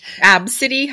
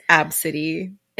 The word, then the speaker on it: Abcde: People that name their kids Absidy. Have you heard that one? It's ABCDE and it's pronounced Absidy.